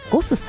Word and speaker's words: GOSS: ゴスさん、 GOSS と書いてゴスさん、そしてお寿司屋さんが 2 店舗ございます。